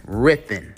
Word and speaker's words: writhen: Our word of the day is writhen. Writhen is spelled W-R-I-T-H-E-N. Writhen. It's spelled with a W, but it's pronounced basically R-I-T-H-E-N, but you spell it W-R-I-T-H-E-N. Writhen is the adjective.